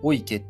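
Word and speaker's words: 雄池: あの、オス池って書いて、お池って読むんですけど、あとメ、メス池って書いて、メ池っていう、お池とメ池がこう並んでいるんですよね。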